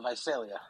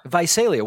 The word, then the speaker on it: Visalia: Let's go next to, why don't we go from Donnie from, is it pronounced Visalia? Visalia? – It's Visalia. – Visalia.